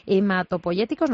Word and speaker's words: hematopoyéticos: Pero como ahora nos está explicando el doctor Cabrera esto de trasplantes hematopoyéticos, no sé si lo he dicho bien. – Sí, perfecto.